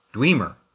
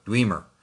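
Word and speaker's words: Dwemer: Okay, thanks to the amazing abilities of the internet, the word is actually pronounced Dweemer. There are other pronunciations that you might use, but you know, five different sites all say Dweemer.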